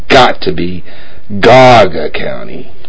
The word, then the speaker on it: Geauga: This is Tony Dean, and I believe it is pronounced Geauga. – I'm telling you, it's Geauga.